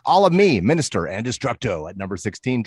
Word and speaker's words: Mxnxstxr: Yeah, Minister. It's spelled Minister. Very strange. – Yes, yes. – As we've covered, it's normal. MXNXSTXR. – Replaced all the other vowels with X's.